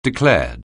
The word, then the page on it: declared: anglais